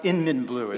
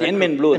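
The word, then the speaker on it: YInMn: Now it's pronounced Inmin blue. – It's Inmin blue, that's why.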